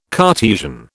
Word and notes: Cartesian: British English

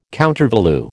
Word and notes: countervalue: American English

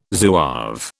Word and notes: Zouave: British English

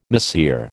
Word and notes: mahseer: American English

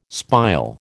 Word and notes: spile: American English

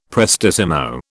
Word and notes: prestissimo: British English

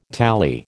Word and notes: tally: American English

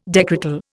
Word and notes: decretal: Indian

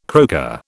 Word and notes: croaker: British English

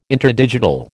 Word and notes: interdigital: American English

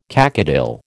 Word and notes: cacodyl: American English

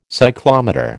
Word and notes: cyclometer: American English

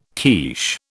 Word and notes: quiche: British English

